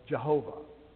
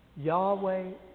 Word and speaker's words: Yahweh: How do you pronounce YHWH? Well, we just insert vowels and call it Yahweh. Or if you were German, you'd call it Jehovah. Same thing. Yahweh Elohim.